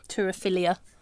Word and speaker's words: turophilia: Your waterphilia? – Turophilia. – Is that what it's called? – Yeah. – I did not say that. Well, how do you spell that? – I think that's how you pronounce it. Turophilia. Yeah, T-U-R-O and then philia.